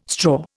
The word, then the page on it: straw: Indian